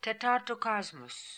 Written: Tetartocosmos

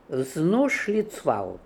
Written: Vznooshlitzval

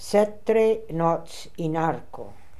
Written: Setrenotzinarco

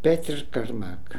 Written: Petrkarmak

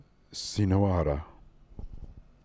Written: Sino ora

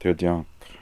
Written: Tirdiank

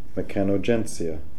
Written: mechanogentsia

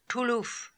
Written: Tooloof

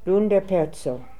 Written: Loonderperzo

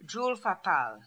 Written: Djoolfapal

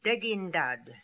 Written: Degindad

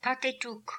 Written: Patetook

